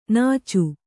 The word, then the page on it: ನಾಚ್: nāc